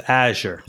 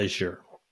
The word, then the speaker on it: Azure: It's Azure, not Azure.